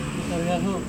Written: neta vehaho

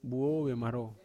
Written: buo vemarou

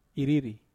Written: riri

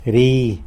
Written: ri

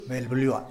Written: belbliua

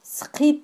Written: sqit

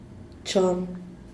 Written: chon

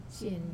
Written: sien

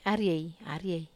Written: arie

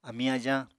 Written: amiñ-ajañ

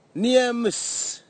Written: niamis

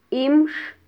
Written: imx

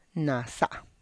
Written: nasa

